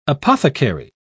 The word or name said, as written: apothecary